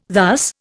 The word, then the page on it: thus: Indian